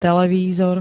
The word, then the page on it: televízor: [t-]